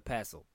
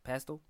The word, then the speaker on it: pestle: Yep, and fatally, he hit her on the head with a pestle. – Pistol? – No. – Oh. – Pestle. – How do you spell that? – P-E-S-T-L-E. – P-E-S-T-L-E? – Yeah.